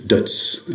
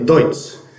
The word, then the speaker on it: Deutz: Now people pronounce it like this, Dutz, in France. – Yes, and how would you pronounce it in Germany? – Dutz. – Dutz. Well, a lot of people say Deutz. – Yeah, some people can say Deutz.